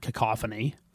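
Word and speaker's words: Kakophoni: This word makes you want to pronounce it cacophony, but it's definitely cacophony. – Yep, 100%. – But it's K-A-K-A, which makes it, for some reason, makes you want to say cacophony.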